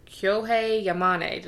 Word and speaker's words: Kyohei Yamane: And this is where we meet Professor Kyohei Yamane.